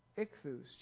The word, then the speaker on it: ichthus: The Greek word for fish is ichthus, which are the letters that you usually see in those fish. They're Greek letters. They're not English letters. Ichthus is the how you say it in Greek.